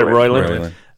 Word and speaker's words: Roiland: Is it Roiland or Roland? Justin Roland. Roiland. I can't remember. Roiland. I think it's Roiland. Yeah, Roiland.